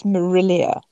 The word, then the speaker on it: Marillier: Can you just tell me how to say your last name again? – It's Marillier.